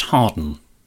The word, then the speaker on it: Hawarden: And also, James Garner is from North Wales, from a village that is spelled H-A-W-A-R-D-E-N, but it's pronounced Harden.